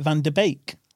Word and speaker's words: Van der Beek: Is that how you say it, Van der Beek? Like a steak bake? – Yeah, I would.